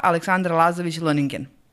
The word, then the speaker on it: Aleksandra Lazović-Lønningen: E, moja današnja gošća je osnivačica Eat Me Up-a, Aleksandra Lazović-Loningen sam dobro sad izgovorila.